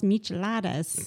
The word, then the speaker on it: Michelada: So at the end, and I don't like the taste of beer, but I had one of those micheladas.